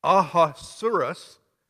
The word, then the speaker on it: Ahasuerus: Now, the Persian king here in verse 1 pronounced Ahasuerus, or however you want to pronounce it. I don't know where they got that word because the Hebrew pronunciation is Akash Werosh.